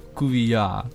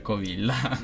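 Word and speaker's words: Covilhã: Il nome esatto della città Coviglia è Cuvillà, perché c'ha un accento particolare sulla A finale, e quindi la pronuncia è molto particolare, quella portoghese, però noi possiamo benissimo dire Coviglia.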